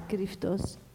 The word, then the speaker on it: Kryptos: Cryptos is a Greek word. K-R-Y-P-T-O-S. Or how do we pronounce it? – King about, it's Cryptos.